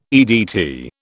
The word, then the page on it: EDT: British English